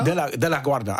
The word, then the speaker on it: De La Guarda: De La Guarda, also nicht ganz wie der Flughafen.